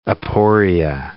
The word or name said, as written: aporia